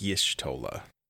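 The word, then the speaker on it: Y'shtola: Yes, it's Yshtola.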